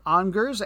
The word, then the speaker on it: Angers: He played in Nantes, and I'm going to pronounce it Angers, A-N-G-E-R-S.